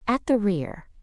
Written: at the rear

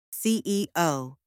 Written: CEO

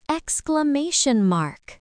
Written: exclamation mark

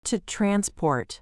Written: to transport

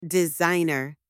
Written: designer

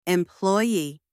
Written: employee

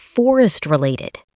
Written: Forest-related

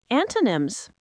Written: antonyms

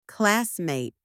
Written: classmate

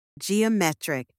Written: geometric

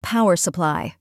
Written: power supply

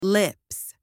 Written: lips

